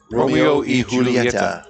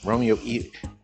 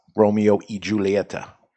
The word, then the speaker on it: Romeo y Julieta: Romeo y Julieta. – E Julieta. – Romeo E. – Not Julieta. – Not Y. Even though it's a Y. Romeo or Romeo and Julieta, which people say all these things. And here I am all these years in it. And how do I say it? The number one selling cigar, Romeo y Julieta.